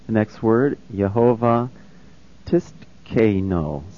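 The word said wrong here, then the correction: Tsidkenu: Sidkenu. – Right, that's what I said. And I guess that T is silent.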